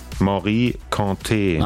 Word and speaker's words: Morikante: Wie wird's ausgesprochen? – Morikante. – Ach so, guck mal. Das war also falsch.